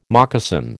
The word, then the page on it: moccasin: American English